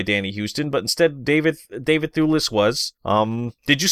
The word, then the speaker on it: Thewlis: So David Thewlis, and I believe that's how you say it, Thewlis as Ares.